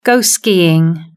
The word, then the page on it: go skiing: British English